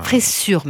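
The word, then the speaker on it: fressure: Vous savez ce que c'est, la fraisure? – La fraisure, non, j'ai du mal à... – Fraissure, même. Il y a deux S, c'est moi qui le prononce mal.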